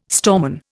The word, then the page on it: storeman: Indian